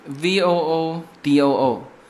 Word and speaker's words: voodoo: 卖给美国是一八零三年嘛，所以然后在法国人来之前，这里还有印第安的文化，我们今天在这个酒吧街旁边的那个商店走嘛，它这边有一个非常有意思的，叫做乌杜，叫做乌杜文化（V O O D O O）。那么这个发音就很像乌古，我我不知道乌古是不是从这个词呃直接翻过来的。那反正这一边的发音就叫这个 voodoo。